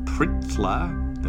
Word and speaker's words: Pritla: Elkar, Lutbun, Lax, Pritla, there's no vowel in that first syllable, Newt, and Vela.